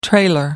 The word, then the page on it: trailer: American English